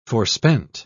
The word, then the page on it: forspent: British English